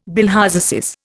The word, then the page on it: bilharziasis: Indian